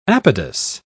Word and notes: apodous: British English

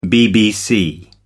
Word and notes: bbc: American English

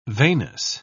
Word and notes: veinous: British English

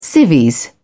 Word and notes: civvies: British English

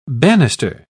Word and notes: bannister: British English

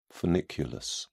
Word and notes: funiculus: British English

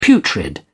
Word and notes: putrid: British English

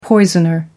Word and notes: poisoner: American English

